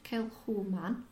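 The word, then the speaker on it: Kilchoman: Established 2005, Kilhoman, pronounced Kilhoman, is one of the smallest distilleries in Scotland.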